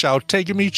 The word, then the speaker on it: Outagamie: Which provides Outagamie, apparently, is how that's pronounced.